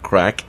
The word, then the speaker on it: craic: And we have this famous word, crack. The crack in Ireland, crack is spelled C-R-A-I-C, and not to be mistaken with other meanings of that word.